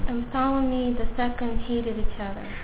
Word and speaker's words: Ptolemy: Ptolemy, да, я правильно сказал, отдал свою дочь.